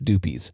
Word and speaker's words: Dupuis: It's called Dupuis. That's how I would say it. The locals say it, Dupies. So Dupies, however you pronounce it.